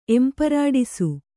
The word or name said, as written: ಎಂಪರಾಡಿಸು